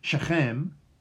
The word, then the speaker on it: Shechem: Shechem.